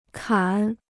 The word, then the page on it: 砍: kǎn